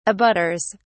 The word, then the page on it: abutters: British English